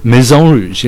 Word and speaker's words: Maison Rouge: It's Maison Rouge? – Maison Rouge, yeah. Okay, it's French.